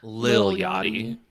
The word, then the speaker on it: Lil Yachty: Somebody who recently surprised me, I honestly never thought I would say this, was Little Yachty. I feel like I'm saying his name wrong. Little Yachty. – Lil Yachty. You're saying it right. Just say Lil, not Little.